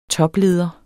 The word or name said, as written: TOPLEDERNE